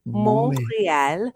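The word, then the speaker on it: Montréal: Montreal. Montreal. You don't say the T.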